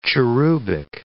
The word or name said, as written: cherubic